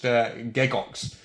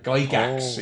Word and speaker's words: Gygax: Gygax is how my family pronounces my name, although in the proper Swiss it was originally pronounced Gegox. But Gygax is how the name is pronounced.